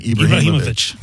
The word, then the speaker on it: Ibrahimovic: But the media, Zlatan, how do you say his last name? – Zlatan Ibrahimovic.